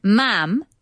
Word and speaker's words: ma'am: says the queen is addressed as your majesty and then ma'am to rhyme with ham, not farm.